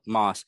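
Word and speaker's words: Maas: In the book, the Van Papers, uh, from 1967, uh, 1969, uh, by Peter Massa as I said that right? Oh, Moss.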